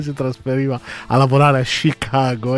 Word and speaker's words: Chicago: Anzi, anche lì si dice, ho scoperto che si dice Chicago. – Sci e anche la A, non è proprio una A, ma proprio loro la pronunciano un po' con una mezza O, un Chicago.